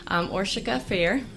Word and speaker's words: Orsika Fejer: ORSIKA Fair.